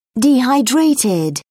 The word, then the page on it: dehydrated: British English